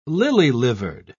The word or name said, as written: lily-livered